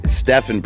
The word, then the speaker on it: Stephen: In school, when you read my real name off of paper, people call it Steven. Stephen pronounced, but it's S-T-E-P-H-E-N. So since people started calling me Steven, I added to Spielberg, as in like the outer space movie thought.